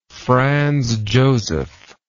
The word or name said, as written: Franz Josef